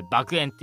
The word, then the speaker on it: 爆炎: なんとね、このスバ 3 期はね、前々からね、えー、言われてましたけども、なんとね、スピンオフ、この素晴らしい世界に爆炎を、多分これ爆炎って読みます。はい、調べました。